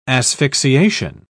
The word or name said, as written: asphyxiation